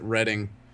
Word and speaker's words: Reading: which is retarded because it's spelled Reading and they pronounce it Reading. Not sure why.